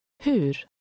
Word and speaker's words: hur: Hur? Hur? – Notice the final R sound, which is different than in English.